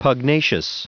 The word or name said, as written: pugnacious